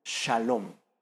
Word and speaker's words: shalom: De hecho, en hebreo, la palabra hebrea que se pronuncia como paz en español, en hebreo se pronuncia como shalom, shalom.